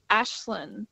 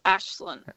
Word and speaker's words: Aislinn: So my name's pronounced Ashlyn. But it's spelled A-I-S-L-I-N-N. – Hang on, let me write that down. – A-I-S-L-I-N-N. – Double N, yes. – Aislinn. – But it's pronounced Ashlyn.